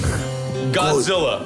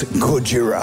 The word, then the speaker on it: Gojira: So I present to you guys Godzilla. – No, it's, you pronounce that wrong. It's Gojira.